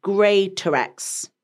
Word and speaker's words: Greatorex: Greatorex. – Yeah. – So it's pronounced Grey, Ter, so T-R-X.